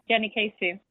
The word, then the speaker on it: Jenny Keisu: Yes, Jenny Keisu.